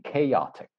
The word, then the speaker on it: chaotic: So just one, the pronunciation I would say, chaotic.